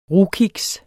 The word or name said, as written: rugkiks